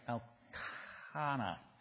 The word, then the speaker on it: Elkanah: But we're pronouncing her name Peninnah, if we're consistent, and his name Elkanah.